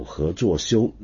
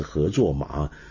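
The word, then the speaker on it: 何祚庥: 那么后面呢有些名字呢，比如说有何作修，那么但是我上次念是何作马，哎呀真是太蠢了。我回头看了一下为什么会念成何作马呢，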